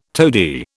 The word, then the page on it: toady: British English